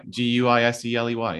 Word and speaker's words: Guiseley: How do you pronounce this? Is it Geisley? – What? A team. A team called Guiseley. – Yeah, in West Yorkshire. – Guiseley sounds about right. Guiseley, yeah? – Yeah.